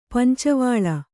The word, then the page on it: ಪಂಚವಳ್ಳಿ: pancavaḷḷi